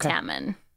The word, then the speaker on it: Tammin: So my name, I changed from Tamin to Tamin. But wait. It was T-A-M-Y-N. – Okay. – And I changed it to T-A-M-M-I-N because no one could pronounce T-A-M-Y-N because they would pronounce it Tamyun.